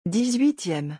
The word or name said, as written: 18eme